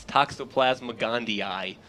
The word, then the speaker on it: Toxoplasma gondii: It's Toxoplasma gondii.